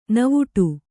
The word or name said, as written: ನಾವಾಟ